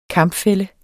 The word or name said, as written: KAMPS